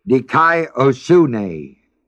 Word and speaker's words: dikaiosune: The word righteousness, which we now must study very carefully, is the Greek word dikaiosune, D-I-K-A-I, O-S-U-N-E, long E.